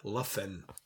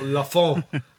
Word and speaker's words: La Fin: And we can now pronounce it right. La Fin. – La Fin. – Not La Fon, or whatever Dave called it.